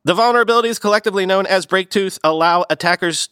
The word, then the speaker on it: BrakTooth: Researchers have uncovered Braketooth, which I believe is pronounced that way, even though it's spelled B-R-A-K-T-O-O-T-H,